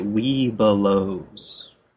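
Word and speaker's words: Webelos: It's really important to draw out one of the middle syllables and to make it weebelows. – Weebelows. Okay, so you draw out the B.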